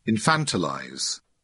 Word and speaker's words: infantilize: Is that how you pronounce the word? Infantilize? How do you pronounce the word? Turn these players into infants. I know I'm looking at the wrong person. – What? Stugatz. – Infantilize. – Thank you.